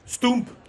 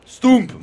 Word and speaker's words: stoemp: Stump. – Stump, bravo, ça s'écrit S-T-O-E-M-P. – Voilà, O-E, il faut le savoir. Ouh. Ça se prononce OU, voilà, c'est tout simple.